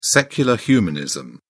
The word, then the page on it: secular humanism: British English